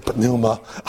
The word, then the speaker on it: pneuma: And the P is not silent in Greek. Unfortunately, it makes it a little difficult, but you know, whenever we say pneuma, the P has got to be there. It's got to be pronounced. You know, in English, it's silent, but in Greek, we have to say it.